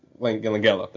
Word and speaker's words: Langella: Yeah, Frank Langella, I believe, if I pronounced it right. Frank Langella. Langella, there you go.